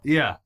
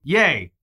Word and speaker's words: Ye: Just Ye. No middle or last name. And I believe I am pronouncing that right, because there is some argument online whether it's Ye, like Jesus, or Ye, like Kanye.